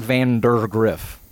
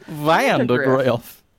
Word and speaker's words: Vandegrift: Yeah, because it's not Vandegrift. – Vandegrift. – It's Vanda, like Pandegrift. – Vandegrift. – Rhymes with Pandegrift.